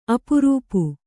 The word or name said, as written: ಅಪರೂಪ